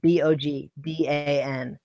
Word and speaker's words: Bogdan: So B O G D A N. – Um, I think they say they say Bogdan, but like if you just spelling it phonetically, it's Bogdan Bogdanovic.